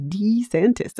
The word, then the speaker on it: DeSantis: I think we got to adjust our pronunciation. It really is DeSantis, according to him, I think.